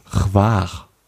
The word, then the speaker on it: Hvar: Hvar. Hvar. Also wird geschrieben H-V-A-R. Also danke heißt Hvala. Hvala, das wird so ähnlich ausgesprochen.